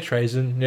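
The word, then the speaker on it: Trazyn: I don't know if that's how you say it. Trazen? Trazen? Doesn't matter. – Yeah, Trazen, yeah.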